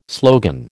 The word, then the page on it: slogan: American English